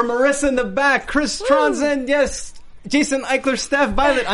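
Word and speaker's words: Trondsen: The best thing, I always tweet out all our interviews, is just go to @myname, it's Chris, and then Tronson, Tron like the old school movie, silent D, S-E-N.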